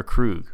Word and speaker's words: Kruge: So, how do you say Krug? Do you say Kruge or Krug? – I've always said Krug. Commander Krug. – Okay. – Yeah. – Good. Correct.